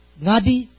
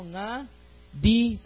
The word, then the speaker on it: Ngadi: It's Ngadi Sastra. So this is a silent G, so Ngadi Sastra.